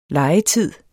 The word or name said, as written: legetid